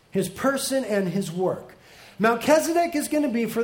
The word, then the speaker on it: Melchizedek: Melchizedek, I pronounce it slightly incorrect. It's actually Melchizedek, but that's harder to say.